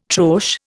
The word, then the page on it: troche: Indian